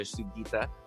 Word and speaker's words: Sugita: Now, today, we've got Tricia Sugita, CEO of FlyQuest.